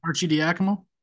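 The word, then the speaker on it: Arcidiacono: How do you pronounce it? Do you know? – Archie Diacomo. – Arciniako, yeah. God, you're good.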